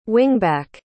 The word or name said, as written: wingback